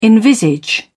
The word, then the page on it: envisage: British English